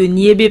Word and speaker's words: niébé: Encore appelé ragoût de niébé, parce qu'au Sénégal, Oui. – le haricot est appelé niébé. – Niémé. – Niébé. – Niébé.